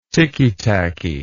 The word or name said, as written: tickytacky